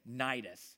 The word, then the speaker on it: Cnidus: We made slow headway for many days and had difficulty arriving off of Sidon, or it's actually, the sea is silent, and most people say Cnidus.